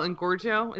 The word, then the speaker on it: Engorgio: But like what if you use what is that spell Engorgio?